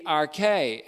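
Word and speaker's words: arche (ἀρχή): but also the arche, A-R-C-H, long E, the beginning, as well as the prototokos, or the firstborn from the dead, in Colossians 1:18.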